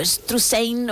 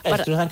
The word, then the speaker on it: Struzan: Bueno, pues Drew Struzan, o Struzan, o Struzain, o como cada uno lo quiera pronunciar, nosotros decimos Struzan. – Struzan, que es más fácil.